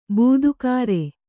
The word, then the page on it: ಬಾಧಾಕರ: bādhākara